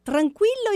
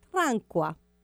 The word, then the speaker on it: tranquillo: Tranquillo is the right Italian word. – Tranquillo, okay. – But in a young style, now they say tranqua.